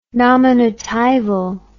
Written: nominatival

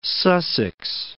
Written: East Sussex

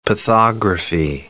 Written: pathography

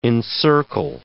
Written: encircle

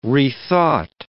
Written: rethought

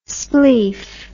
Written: spleef

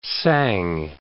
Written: sang

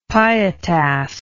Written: pietas

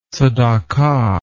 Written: tzedakah

